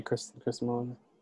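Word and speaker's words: Kristin Milioti: Kristen Milioti.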